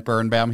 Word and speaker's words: Birnbaum: Brent Birnbaum here.